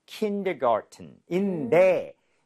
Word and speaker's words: kindergarten: kindergarten인데, 이게 현실적으로는요, garden 발음할 때, T 사운드를 거의 안 내요. – 아, 그래요? – 네, 그래서 kindergarten처럼 은처럼 발음하는 경우가 굉장히 많습니다. 그래서 들을 때 조금 주의하셔야 되기는 해요. 그래서 네네. – kindergarten or kindergarten 이런 식으로 연습을 좀 했으면 좋겠고요.